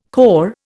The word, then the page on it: cor: Indian